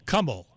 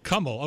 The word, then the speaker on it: Kamal: The pronunciation of my name is Kamal. It's slightly different from Kamal.